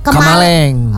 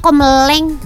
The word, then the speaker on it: Kamaleng: Pagi semua yang di studio mau request dong lagu Enmesh Kamaleng. – Oh. Aku tadi bacanya Komeleng. – Salah.